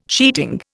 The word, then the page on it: sheeting: Indian